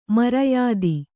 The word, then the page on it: ಮರ್ಯಾದೆ: maryāde